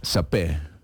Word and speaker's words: saper: Yo creo que la R al final no se pronuncia, pero pues no sé. – Sapé. – Sí.